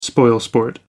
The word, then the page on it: spoilsport: American English